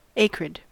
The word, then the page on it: acrid: American English